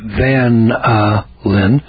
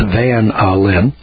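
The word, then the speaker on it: vanillin: chemical flavors such as vanilla, pronounced van-a-lin, that's van-a-lin, chemical colors, and carrageenan.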